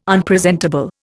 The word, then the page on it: Unpresentable: Indian